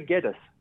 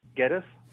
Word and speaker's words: Geddes: We say Geddes. – Geddes. Yeah. Geddes. How do you pronounce it? – I would say like lettuce, Geddes.